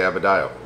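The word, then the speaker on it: Adebayo: I was thinking, how am I going to say Abadayo? – Yeah. – Did I say it right? – You said it right.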